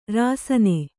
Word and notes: ರಾಸನಾ: rāsanā

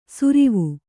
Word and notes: ಸುರಿವು: surivu

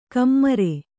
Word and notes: ಕಮ್ಮರೆ: kammare

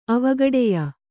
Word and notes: ಅವಗಡೆಯ: avagaḍeya

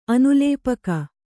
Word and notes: ಅನುಲೇಪಕ: kannada

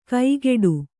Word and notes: ಕೈಗೆಡು: kaigeḍu

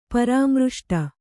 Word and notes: ಪರಾಮೃಷ್ಟ: Kannada

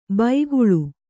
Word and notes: ಬೈಗುಳು: baiguḷu

